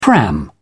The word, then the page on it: pram: British English